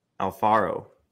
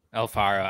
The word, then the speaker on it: Alfaro: I think the last uh, last one was Alfano, Alfonso, right? – Alfaro. – Alfaro. I just butchered that so Come on, bad.